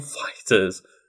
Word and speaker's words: FighterZ: It took me a long time to pronounce it FighterZ instead of Dragon Ball FighterZ, which sounds way cool. It's called Dragon Ball Z, and then they make a game called Dragon Ball Fighter with a Z at the end, and they pronounce it FighterZ, not Dragon Ball FighterZ.